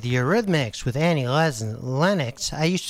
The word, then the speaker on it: Eurythmics: I added a couple extra syllables in there. That's the Eurythmics.